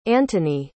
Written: antony

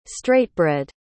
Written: straightbred

